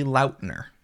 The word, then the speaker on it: Lautner: It is my fault that nobody knows how it's actually pronounced because I never corrected anybody for decades. – No way. – It is technically Lautner.